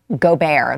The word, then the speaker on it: Gobert: Gobert, that's how they're telling me that it's pronounced.